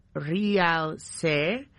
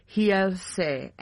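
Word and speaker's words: Realce: At least that's how it's spelt, but I'm sure I gotta get my pronunciation correct. Yeah, the R's are H's, right? – Yes. – So I guess it'd be Hielse. And okay.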